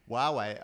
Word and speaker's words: Huawei: Yeah, you just treat it like it's a W. Yeah. The so H-U-A, you just go, nah. – It's my way or the Huawei.